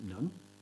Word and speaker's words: nun: I'm going to pronounce it nun.